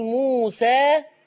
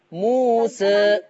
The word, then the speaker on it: موسى: أنا احنا مش بنكتب كلمه موسى بالياء. بس بتتنطق الف. ادي اسمها ذوات ياء، يعني ده مؤقتا. فذوات الياء لورش يا اما بيفتحها زي حفصه عادي جدا يقول موسى، يا اما بيقللها بيبتدي يميل بالفك بتاعه شويه موسى.